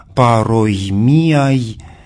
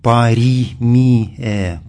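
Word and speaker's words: пароймиай (παροιμίαι): Эти притчи, эти краткие изречения, содержащие некую мудрость, называются по-гречески словом, которое в древнем классическом чтении звучит как «пароймиай», а для византийского, более позднего произношения характерно превращение сочетания «ой» в «и», и сочетание «ай» в «э», и поэтому получается в византийском более позднем чтении «паримие», «паримие». И в связи с этим в русской литературе встречалось написание через «и» – «паримии».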